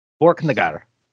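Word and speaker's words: Borknagar: Have I been saying that correctly? Borkenegard. – That's with it. That's all good.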